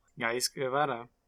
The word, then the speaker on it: Ghais Guevara: Ghais Guevara. Do you want to introduce yourself? that was a beautiful pronunciation.